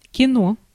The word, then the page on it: movie: /ˈmuː.vi/